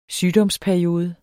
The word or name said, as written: sygdomsperiode